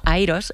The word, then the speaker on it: IROS: En el 2018 organizamos en la Universidad Carlos III de Madrid una conferencia que se llama IROS. Bueno, en inglés la pronunciamos como AIROS.